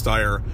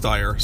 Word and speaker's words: Steyer: And that's Tom Steyer, however you pronounce his name. I think it's Steyer. And I should know this by now. I've heard a bunch of different ways it's said, but it's Steyer.